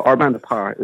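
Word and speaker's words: Bande à Part: So Our Band Apart is correct pronunciation, but we don't expect anybody to... Well, I don't expect anybody in America to get that right.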